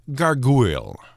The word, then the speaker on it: gargouille: Or rather, to be named gargoyle. It's pronounced the same, but spelled G-A-R-G-O-U-I-L-L-E.